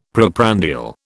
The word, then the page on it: preprandial: British English